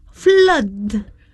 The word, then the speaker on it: flood: Flood or flood? – Flood or flood? Okay, it sounds like blood. Okay, yeah. So you say flood.